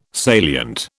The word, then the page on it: salient: British English